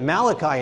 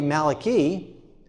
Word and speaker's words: Malachi: We pronounce it Malachi in English, but it'd really be Malachi.